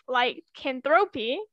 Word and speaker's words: lycanthropy: The accusation of lycanthropy, said it right this time.